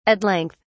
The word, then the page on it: at length: British English